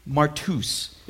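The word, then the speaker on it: martus (μάρτυς): This word witness is actually pronounced in the Greek, martus. It's where we get our word martyr, right?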